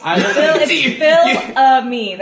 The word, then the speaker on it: Fils-Aimé: Phil's a me. – Oh. Phil's a me. – Oh, is that how you pronounce it? – Apparently. – It's Phil a me. That's how it is.